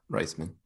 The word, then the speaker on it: Reisman: Did I say that right, Jonathan? – Yep, Reisman.